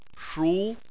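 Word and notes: shu: tone 2

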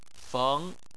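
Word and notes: feng: tone 2

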